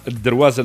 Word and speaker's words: درواز: أستاذة فاتن المولدي درواز، الدرواز النطق صحيح هكا ولا؟ – صحيح.